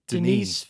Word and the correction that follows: Denis Villeneuve: Denise Vin- Denis Villeneuve. – That sounds good.